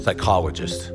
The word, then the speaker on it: psychologist: Psychologist has a silent P at the start of it.